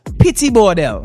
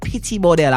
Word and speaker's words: Petit Bordel: It's Pity Bordel, right? Ah, hold on. The right way to spell it is P-E-T-I-T-B-O-R-D-E-L, right? Big up Petit Bordel. How we call it Pity Bordel or Pito, right?